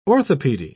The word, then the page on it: orthopedy: British English